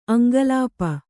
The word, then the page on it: ಅಂಕಲಿಪಿ: aŋkalipi